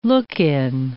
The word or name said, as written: look-in